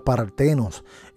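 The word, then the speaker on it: partenos (παρθένος): Ellos traducen como ese término pantenos, algo así, ¿verdad? Como se pronuncia partenos.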